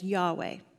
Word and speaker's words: Yahweh: Now, in the original language, "I am" is represented in four Hebrew characters, YHWH, which we say as Yahweh. That's how we pronounce it.